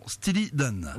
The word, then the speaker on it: Steely Dan: Stilly Dan.